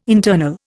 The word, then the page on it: internal: Indian